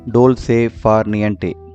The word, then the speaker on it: dolce far niente: The word of the day is from the Italian language and is pronounced dolce far niente.